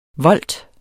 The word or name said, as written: volt